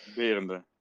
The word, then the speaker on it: Berende: Berende.